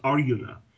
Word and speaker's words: Arjuna: In the Bhagavad Gita and in the Mahabharata, the character's name is Arjuna, or it's actually pronounced Aryuna,